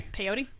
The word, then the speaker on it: peyote: Peyote. – That's the one. Rhymes with coyote.